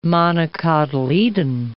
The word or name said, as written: monocotyledon